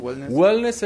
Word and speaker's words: wellness: Platícanos Wellness, lo estoy pronunciando bien. wellness. – es de, bien, de well es como salud, bien, estar bien, wellness.